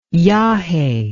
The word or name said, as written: yaje